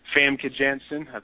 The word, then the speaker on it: Famke Janssen: And of course, it also has some guest appearances by Famke Janssen. That's how you say her name, Jean Grey, as a character in the movie.